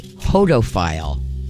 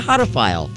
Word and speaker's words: hodophile: What is, I think I'm pronouncing this right, what is a hodophile? H-O-D-O-P-H-I-L-E. Hodophile. Or hodophile. Yeah. You'll understand why I'm asking that when I give you the answer on the next break. That's your Afternoon Mindbender. What's a hodophile?